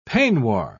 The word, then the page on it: peignoir: British English